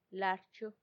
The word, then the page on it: larchu: Macuiltianguis